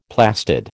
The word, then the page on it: plastid: American English